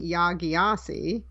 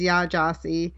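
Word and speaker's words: Yaa Gyasi: It was through Pen Out Loud, and it was with Yah Gyasi, which is the author that I was pronouncing Yah Gyasi. So I learned the pronunciation is Yah Gyasi, and it was moderated by Roxane Gay.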